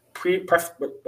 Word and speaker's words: Ibaraki: And somebody says, according to the police, the suspect allegedly sold 3 DVDs to 2 women in Ibaraki. Ibaraki prefecture.